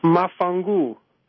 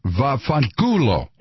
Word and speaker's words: vaffanculo: It's mafangu. – That's something, no, I'm telling you, it's vaffanculo.